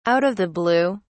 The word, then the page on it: out of the blue: British English